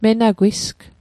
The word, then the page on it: menagwisg: Mi'gmaq